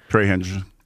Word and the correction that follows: Trey Hendrickson: Trey Hendrickson.